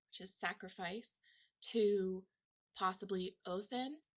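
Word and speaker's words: Óðinn: So Odin is spelled O, the D that curls with the line through it, I-N-N, that is pronounced Othin with a T-H.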